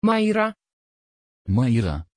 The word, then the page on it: Mayra: Dutch